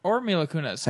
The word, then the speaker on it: Mila Kunis: Or Mila Kunis.